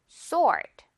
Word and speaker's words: sword: 자, 그런데 sword라고 발음된다는 거. ord 이 발음 그대로 하면서 s만 붙여주면 돼요. 앞에. sword.